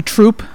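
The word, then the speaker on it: Throop: Well, first we got to pronounce THRROOP like a Southsider. – Oh, sorry, I'm not saying troop. – It's troop. – Yeah, you're not saying it. Southsider would call it troop.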